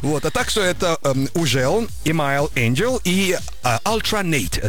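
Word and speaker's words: Hugel: Трек называется «Ужел», а кто-то говорит «Хюгел». – Да как мы его только не называли в свое время. «Ужел», «Хюгел», да? «Хюгель». – Самое правильное произношение – это «Ужел». Он француз.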